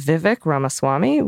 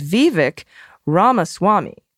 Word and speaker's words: Vivek Ramaswamy: I think the name you're saying is Vivek Ramaswamy, which is Vivek Ramaswamy. All right, great. A Ramaswamy. So maybe there's two, like a Ami. I've exaggerated the stresses, but they really should just be the slightest stretches.